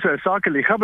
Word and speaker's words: Sakeliga: Yes, our name is Sakeliga, but you did very well. – Okay, Sakeliga.